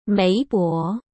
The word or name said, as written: Mei Bo